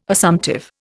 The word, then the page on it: Assumptive: Indian